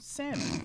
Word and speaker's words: salmon: I'm not trying to be disrespectful or anything like that, but, you know, it's pronounced salmon. You know what I'm saying? And she's like, but it has an L.